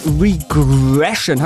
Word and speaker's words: Regression: Der Film heißt Regression. Habe ich das richtig korrekt ausgesprochen? – Hast du, perfekt.